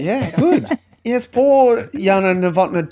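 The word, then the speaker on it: Jana: You pronounce the J with a Y. – So not Yelena. – Not Yelena. – Yana.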